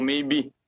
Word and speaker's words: Maibee: E o Bi, na verdade, seria Meibri, né? Como não ficou uma pronúncia tão interessante, tão, tão bacana, eu alterei um, um, o R pelo E. Aí ficou Maybe.